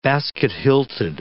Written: basket-hilted

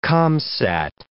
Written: Comsat

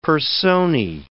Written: personae

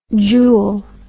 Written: jule

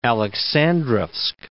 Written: Aleksandrovsk

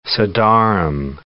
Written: sedarim

